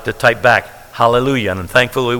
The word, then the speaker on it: hallelujah: And if you spell that out, it ends with J-A-H. It doesn't end with Y-A-H, though we pronounce it that way. We don't say hallelujah. It's hallelujah. Thus, we could say pronounce that ja or ya, but it is praise to Jehovah.